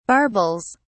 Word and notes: barbels: British English

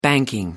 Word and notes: banking: American English

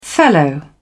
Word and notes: fellow: British English

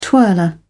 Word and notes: twirler: British English

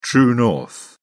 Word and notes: true north: British English

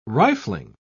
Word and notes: rifling: British English

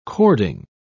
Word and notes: cording: British English

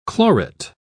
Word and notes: chlorate: British English

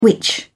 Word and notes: witch: British English

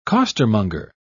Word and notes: costermonger: British English

